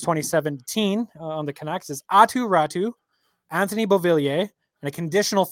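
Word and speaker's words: Aatu Räty: It is Finnish pronunciation, and it is Atu Ratu.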